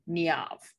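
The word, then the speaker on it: Niamh: It says on the Google that you pronounce this name Niav. – Ooh, I'm in disbelief. To me, it's Niav. The internet says Niav. N-E-E-A-V.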